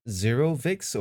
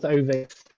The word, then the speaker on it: 0VIX: Is it Zero Vix or is it Ovix? – Pronounced Ovix. – Ovix. – Yeah.